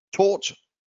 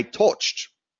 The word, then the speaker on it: taught: Many people make a mistake and they say, I touched. Touched? No. Touched? No.